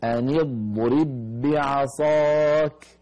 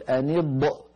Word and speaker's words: أن يضرب: بعض إخواننا يقول أن يضب، أن يضرب، يضب، هذا الصوت لا معنى له، وهو صوت زائد ليس من صوت الضاد، أن يض، انتهى صوت الضاد، أن يضرب، أن يضرب، ولا يكون للضاد ذيول أخرى في نطقها.